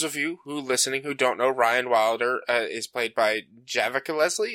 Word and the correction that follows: Javicia: Is that how you speak? – It's a soft C. Hang on, I have her Wikipedia page open somewhere. Pronunciation. It's Javicia.